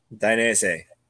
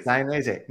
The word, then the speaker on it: Dainese: Proper pronunciation is it Dainese? – Dainese. – Dainese. Now I'm gonna let you know. I have Italian neighbors, and I asked them, and you're dead on.